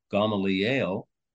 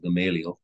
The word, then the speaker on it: Gamaliel: One of the most obvious ones, most obvious examples come later on in the book of Acts from Rabbi Gamaliel, or you pronounce, if you prefer, you can pronounce it Gamaliel.